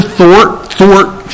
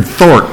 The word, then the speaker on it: thwart: He tries to thwart. He tries to mess up God's plan. Thwart. I said it right, didn't I? Thwart. I was thinking Thort, and that wasn't right.